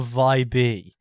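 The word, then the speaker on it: Vibee: Is it vibe or vibe? – I'm saying vibe because there's two E's. So it's V-I-B-E-E.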